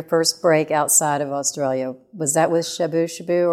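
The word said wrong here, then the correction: Shabooh Shoobah: With Shabu Shaba, that's how you pronounce that.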